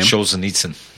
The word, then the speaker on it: Solzhenitsyn: And then if you couple that book with the Gulag Archipelago by Solzhenitsyn, I'm butchering his name. – Solzhenitsyn. – Yes.